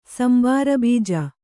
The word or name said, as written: ಸಂಬಾರಬೀಜ